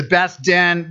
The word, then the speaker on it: Beth Din: Two words, B-E-T-H-D-I-N, the Beth Din. Am I saying that right?